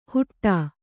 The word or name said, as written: ಹುಟ್ಟಾ